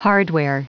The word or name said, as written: hardware